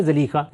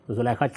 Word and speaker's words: زلیخہ: ہمارے زلیخہ کہتے ہیں ان کے ایسے ہی جیسے، یعنی اصل تلفظ کیا ہے زلیخہ، لیکن یہ کہ ہمارے ہاں لوگوں کی زبانوں پر زلیخہ چڑھا ہوا ہے.